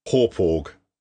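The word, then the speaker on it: Hauppauge: Apparently, the company is based in Long Island, and the place is pronounced Horporg.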